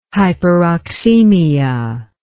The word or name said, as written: hyperoxemia